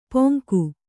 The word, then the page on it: ಪೊಂಗು: kannada